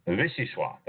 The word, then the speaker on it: Vichyssoise: I probably pronounced that wrong. Vichyssoise. Thank you. My engineer just corrected me from the background here. Vichyssoise.